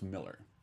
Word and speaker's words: Millar: Alan Kistler would correct you and say that it's pronounced Miller. – Is it? – Yeah. – Always look like Millar. – He corrects me every single, it looks like Millar, all right. But it's Miller. He's like, no, that's the way, it's the British pronunciation of the A.